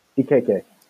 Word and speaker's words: Ekeke: Ekeke.